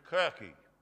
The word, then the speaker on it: Kirche: Church comes from the Old English and German word pronounced kirche.